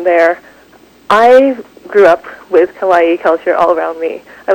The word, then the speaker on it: kawaii: This whole kawaii, I pronounced that correctly, did yep.